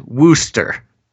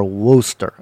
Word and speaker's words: Worcester: I do have to say, Gantz, the overwhelming message I'm getting from the chat is that it's pronounced Wooster. – Oh, Wooster. – Yeah, it looks like some people actually know more than I do about places I've never been or heard of. – W-O-R-C-E-S-T-E-R, Wooster.